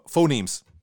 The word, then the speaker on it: phoneme: And within language and the ability to hear the, sorry, I'm going to mispronounce the phenoms. – Phonemes. Phonemes. Although okay, I am a phenom, it's a phoneme.